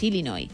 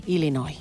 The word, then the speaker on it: Illinois: Pero es Illinois, o sea, si tú lo pronuncias bien y tienes buena dicción, es Illinois. – Illinois. – Luego, si lo pronuncias rápido, según el acento, como decía Beto también, pues te sale una medio E, pero nunca un Illinois.